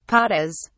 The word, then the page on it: patas: British English